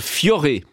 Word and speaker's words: Fiore: Le chanteur tessinois Valentino Vivace avec son dernier single Fiore, ça se prononce comme ça, sur RTS Première.